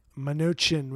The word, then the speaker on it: Mnuchin: Am I pronouncing his name right? – Mnuchin.